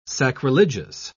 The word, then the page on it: sacrilegious: British English